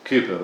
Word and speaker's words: Cowper: Spelt Cowper, pronounced Cooper.